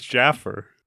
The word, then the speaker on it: Jaffar: And the ultimate boss in the game is a grand vizier who is a wizard named Jafar. – No. His name is Jaffer. – Is it pronounced Jaffer? – Well, so the wizard in this game is J-A-F-F-A-R. The wizard in Aladdin is J-A-F-A-R.